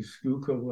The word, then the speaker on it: Schuylkill: And must compliment you on pronouncing Schuylkill like a native Philadelphian.